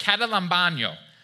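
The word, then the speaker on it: katalambano (καταλαμβάνω): It's pronounced katalambano.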